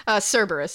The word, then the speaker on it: Cerberus: Working from home and getting a giant stuffed plague doctor because, you know, it's the apocalypse and my stuffed Cerebrus needed company. I love this so much. – Yeah. – Cerberus. Sorry, I said that wrong.